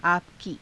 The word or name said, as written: aapa ki